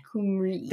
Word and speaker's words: Cymru: And Welsh people say Wales, Cymru. – Cymru. – Though Cymru is spelled C-Y-M-R-U.